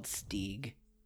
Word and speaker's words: Steg: Steg. He's credited as a Steg, but I think he should be called Steeg. – All right. Well, it is a long E in Steven. That makes sense.